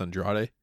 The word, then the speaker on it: Andrade: That match came out of nowhere for me because I wasn't really familiar with Andrade. I'm probably pronouncing that wrong. But no, that's it. It's Andrade.